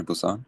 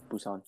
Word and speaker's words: Busan: Train to Busan? – Yeah. Or Busan.